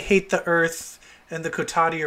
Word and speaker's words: Kotati: Was it the Coterie? Coterie? How do you say that? – Kotati. – Kotati.